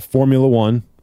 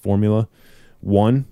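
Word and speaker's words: Phormula-1: Okay, now the one I use is made by First Form, and it's called Formula One. It's spelled with a PH instead of an F, so it's PHORMULA, Formula One.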